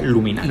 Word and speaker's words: Illuminati: Les, se escribe Illuminati, pero se pronuncia Luminati.